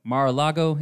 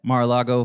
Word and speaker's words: Mar-a-Lago: Trump was raided at Mar-a-Lago. Is that how you say it? – Sure. – Trump was recently raided at Mar-a-Lago for, you know, all of the allegations that have been against him and stuff.